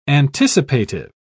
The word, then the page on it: anticipative: British English